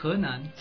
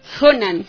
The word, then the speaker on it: Henan: La canción que escuchábamos se llamaba Balada de la provincia del norte de Henan. No sé si lo estoy pronunciando bien. – Henan. – Henan. Gracias, profe.